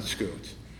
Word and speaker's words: Scoots: Really should be Tam of the Scouts, but because he was Scottish, he pronounced it Scoots.